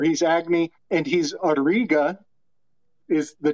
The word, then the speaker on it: Agni: I think it's said Agni, because it's spelled A-G-N-I.